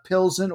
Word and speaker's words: Plzeň: Yeah, P-L-Z-E-N, but in English, they use a little bit different alphabet there, I believe, but it's pronounced Pilsen or Pilsen. That's how I've seen and heard it.